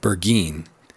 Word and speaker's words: Burgoyne: He pronounced it Burgeen, which my informant used to borrow.